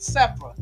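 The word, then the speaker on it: Zipporah: And so Moses' wife was named Sephora. That's Z-I-P-P-O-R-A-H. I like to call her Sephora, but people pronounce it Sephora.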